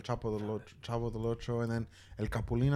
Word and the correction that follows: Chapulín: I think that's what it's called. – Chapulín. – El Chapulín Colorado.